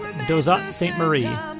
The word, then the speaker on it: Dauzat St. Marie: Together, they make up Dauzat St. Marie. – Hey. How's everybody doing out there? – Look awesome.